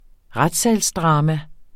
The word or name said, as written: RETSSALSDRAMA